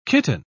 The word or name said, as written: kitten